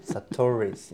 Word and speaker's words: Sahtouris: So, Satori? – I'm usually known as Dr. Elisabet Sahtouris. Sahtouris. Sahtouris, yes.